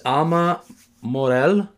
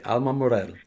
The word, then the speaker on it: Alma Morel: Her name is Alma Morel. Am I saying that right? Alma Morel. I'm saying it with a little bit of Italian accent. Alma Morel. – Yes, Alma Morel. Yeah, you did good.